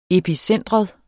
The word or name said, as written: epicentrum